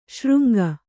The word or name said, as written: ಶೃಂಗಿ